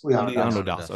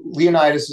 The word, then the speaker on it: Leonidas: You know, you discussed that Leonidas, or how we pronounce, have we agreed on, is it Leonidas or Leonidas? – Leonidas. Okay. – But also, I want to be clear, like, you know, with Greek stuff, it's and also how people pronounce things over time changes. So Leonidas is certainly allowable.